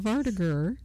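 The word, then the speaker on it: vardøger: According to ancient pages, the stories of Vardagr describe incidents that are basically deja vu in reverse. Well, stop it! – Sorry. – That's how it's pronounced. – I know, you nailed it.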